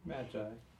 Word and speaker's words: Magi: The Magi Image. Did I say that right? – Magi. – I've heard it both ways. I don't know.